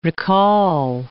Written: recall

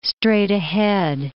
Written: straight-ahead

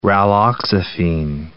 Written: raloxifene

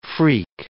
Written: phreak

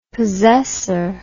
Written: possessor

